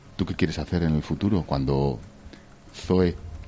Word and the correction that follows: Zoir: lo he dicho bien? – Zoir. – ¿Zoel? – Zoir. – Zoir.